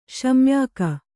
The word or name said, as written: ಶಮ್ಯಾಕ